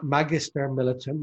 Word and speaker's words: Magister Militum: The image credits, I should thank Magister Millistum. Magister Militum, my Latin teacher would correct me on my pronunciation,